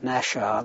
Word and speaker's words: Naxxar: Originally 601 Squadron had lived in a palace called Nashar, that's spelled N-A-X-X-A-R, Nashar Palace, and I think that was in the village of Nashar.